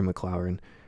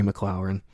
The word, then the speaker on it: McLaurin: Terry McLaurin? I don't know how you... I just butchered that really bad. It's really simple. Sorry. Terry McLaurin, 6 receptions, 102 yards for the Commanders.